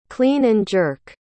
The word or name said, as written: clean-and-jerk